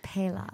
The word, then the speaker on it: Pelas: Pelas.